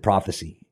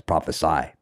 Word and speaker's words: prophesy: So it'll be an interesting conversation, you know, because I kept on pronouncing. Every time I would see it, I guess I didn't notice the difference between the C and the S, so I would always pronounce it prophecy. And then Jeff was like, no. That's prophesy, big difference.